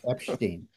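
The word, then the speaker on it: Epshteyn: We have a report that one of Trump's attorneys named Boris Epstein, am I pronouncing that right? – I think you are. You got the right. Yes, Epstein.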